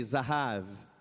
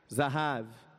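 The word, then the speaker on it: Zahav (זהב): Então, o ouro, a palavra ouro, no original quer dizer Zahav, diga aí, Zahav. Né? Como é que se escreve Zahav? Você tem internet aí, busca aí ouro no hebraico, Zahav.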